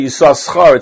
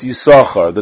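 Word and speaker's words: Yissachar: One is silent, and one is not. You don't say Yisachar, it's Yisachar.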